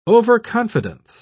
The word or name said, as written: overconfidence